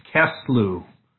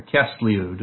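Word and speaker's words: Kislev: The ninth month, the Hebrew name of the ninth month is Kislew, or Kislew, depending on how you want to pronounce that.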